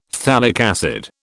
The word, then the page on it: phthalic acid: British English